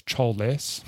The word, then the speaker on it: Choles: it's funny because it's called Chols, like it's written Chols, but they pronounce the glove as Choles.